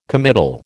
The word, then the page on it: committal: American English